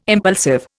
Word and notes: impulsive: Indian

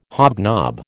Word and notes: hobnob: American English

